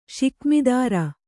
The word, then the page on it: ಷಿಕ್ಮಿದಾರ: ṣikmidāra